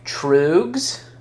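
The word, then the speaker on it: Tröegs: So this beer, Trugs, I don't know how you pronounce that. It's got an umlaut over the hill. Yeah, Trugs. – T-R-O-E-G-S.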